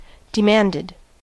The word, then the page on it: demanded: American English